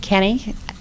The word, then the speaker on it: Kenny: Yes, Judith Kenny. Kenny is K-E-N-N-Y.